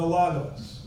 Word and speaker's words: logos: And if you study the word in Greek, the word is logos, L-O-G-O-S. Logos, it's pronounced.